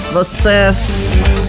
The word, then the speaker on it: vocês: Como diria Dilma Rousseff, vocês. Ela pronuncia corretamente, né? A gente costuma dizer vocês, a gente inventa um i aí na palavra.